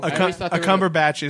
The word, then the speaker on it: Cumberbatch: If you put Benedict Cumberbatch in, God, I just butchered his name. – Benedict Cumberbatch. – Cumberbatch.